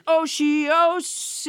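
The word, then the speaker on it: otiose: Last week's word was osios. It sounds a bit like Oreos, but it's not.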